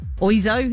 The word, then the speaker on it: Oizo: Mr. Ozio, I never really knew how to say it. Oizo? It's not Ozio, is it? It's the O-I-Z-O. Oizo? Oizo?